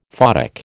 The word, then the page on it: photic: American English